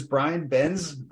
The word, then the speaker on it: Brian Benz: So my name is Brian Benz.